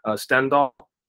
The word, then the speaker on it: Stendhal: Stendhal.